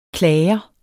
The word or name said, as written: klager